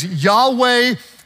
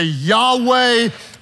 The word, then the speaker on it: Yahweh: It is the phrase Yahweh Wayitin. Everybody say that. Say it aloud. Say Yahweh Wayitin.